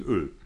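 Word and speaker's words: Øl: Nee, dieses O mit Querstrich durch und dann L. – Okay. – Ausgesprochen Öl.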